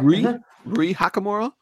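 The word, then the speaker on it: Rui Hachimura: Zach Norwell Jr. and Rui Hakamura. Am I pronouncing that right? – I think you are. – Rui Hakamura. R-U-I.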